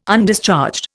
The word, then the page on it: Undischarged: Indian